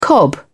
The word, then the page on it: cobnut: British English